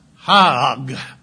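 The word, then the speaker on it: hag (חג): Now, in Strong's concordance, feast for this one is H2282, pronounced hag. Remember, that's the way they say for the people who know that how to pronounce the Hebrew.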